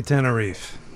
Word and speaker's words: Tenerife: Is it Tenerife or Tenerife? – I say Tenerife.